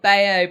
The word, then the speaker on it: Bayeux: is. Bayeux. It's French. It's a little town in Normandy called Bayeux.